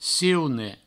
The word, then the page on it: ciune: provençal